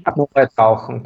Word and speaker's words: Apnoe-Tauchen: Apnoe-Tauchen, genau.